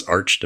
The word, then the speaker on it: arch: I don't know how that would be pronounced given that it is architecture, but reads as arch to me.